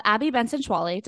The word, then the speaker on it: Abby Benson-Schwallie: We have Abby Benson-Schwallie.